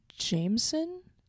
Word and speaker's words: JMSN: And also, JMSN is actually pronounced Jameson.